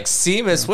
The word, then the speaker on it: Seamus: Anyway, and they pronounced it Seamus, and I was like, wow, I'm an idiot.